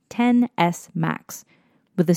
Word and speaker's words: XS: Following in Apple tradition over the years, the X is actually a Roman numeral, so it's pronounced 10, as in the number, and followed by S, as in the letter. Think 10S.